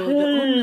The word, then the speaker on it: Köln: It's so hard to pronounce it because it's K-O-L-N in German. Right, and it's the U that gets people, Köln. The umlaut, the O with the two dots.